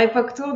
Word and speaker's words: Epok'Tour: Oui, alors époque Tour, donc ça s'écrit E-P-O-K donc on peut réserver sur Époque Tour, euh, comme vous venez de me le dire, mais aussi via le Pass Culture.